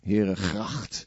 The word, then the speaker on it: Herengracht: Herengracht. That's very good for the throat. Yes. And I'm quite excited that Lord Jagannath is going there, because Lord Jagannath used to live there on the Herengracht.